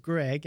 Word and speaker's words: Gregg: And my name is Greg, and it's spelled with two G's. So I have Greg, G-R-E-G-G, which is three G's.